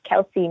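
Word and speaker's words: Kelsi: My name is Kelsey Main. My handle is at Kelsey Main everywhere.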